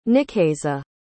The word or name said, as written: nikeiza